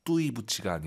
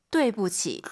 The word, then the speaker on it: 对不起: 그리고 UI 사이에는 에, 뚜이 부치가 아니라 对不起.